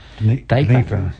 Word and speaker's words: Dnieper: How do you pronounce that? – Dnieper.